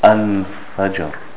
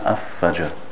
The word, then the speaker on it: al-fajr: For example, we have here al-fajr, al-fajr, not af-fajr.